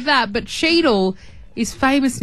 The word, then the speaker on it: Cheadle: Cheadle. So it's spelled C-H-E-A-D-L-E.